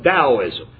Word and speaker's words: Taoism: We have Taoism, which the Chinese T is pronounced like a D. They call it Taoism. And in your things you're going to look up, it's going to be spelled T-A-O-I-S-M, but he's got a spell like Chinese pronounce it, D-A-O.